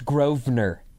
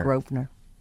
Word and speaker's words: Grosvenor: It's pronounced Grosvenor. – Grosvenor, okay. Were there like 5,000 of those? – Yes. – It's spelled Grosvenor. – It's pronounced Grosvenor. – Grosvenor.